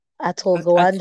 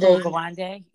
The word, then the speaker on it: Atul Gawande: Everybody should go read the book Being Mortal by, I don't know how to pronounce his last name. Do you know? Atul Gawande. – Atul Gawande, yes. – Something like that, right?